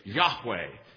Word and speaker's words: Yahweh: Now, when you see L-O-R-D in capital letters, that means that the Hebrew text has the tetragrammaton, the four letters, Y-H-W-H, Yahweh is how we, our best guess is how that is pronounced.